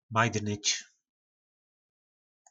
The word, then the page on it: majdnyc: po nasimu